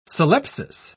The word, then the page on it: syllepsis: British English